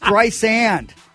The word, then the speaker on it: Streisand: My name isn't spelled with a Z, she argued. it's Streisand like sand on the beach.